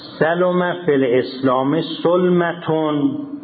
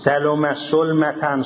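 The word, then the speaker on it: ثلم في الإسلام ثلمة: سلم فی الاسلام سلمتون. دیدم خیلی ها اشتباه اینو هم تلفظ می کنن هم اعراب، متوجه باشید سلم سلمتن سلم سلمتن اینا همه غلطه.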